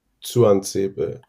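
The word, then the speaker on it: Tuanzebe: Tuan Sebe wird das so ausgesprochen. – Genau. Ich habe mir ein Twitter-Video von Aston Villa mal angeguckt. Der, das T vorne spricht der selber wie so ein Tuan Sebe.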